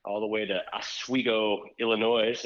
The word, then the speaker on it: Illinois: Illinois. I think the S is silent.